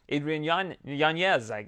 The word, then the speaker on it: Adrian Yanez: Adrian Yanez, that's how Luke Thomas says it's pronounced. That's what Adrian told him, so we're going to roll with that.